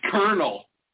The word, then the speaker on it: colonel: The most decent of the silent L words is colonel. – I was going to say colonel. I literally was. C-O-L-O-N-E-L. – The colonel, which is an honorable, respectfully spelled word, is also silent in could, should, would, well, as well as calf and half, chalk, talk, walk, and for many people in calm, palm, and psalm.